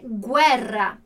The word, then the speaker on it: guerra: E esse é o som que a gente vai ter toda vez que tiver G-U-E juntos, ou G-U-I, que é guerra.